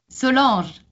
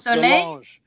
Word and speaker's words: Solange: Solange. Like soleil. Solange. It's like Solange. Soleil in French. It's the sun. And ange is angel. It's like sun angel.